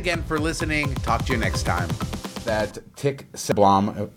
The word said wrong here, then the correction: Segerblom: What a Tick Segerblom.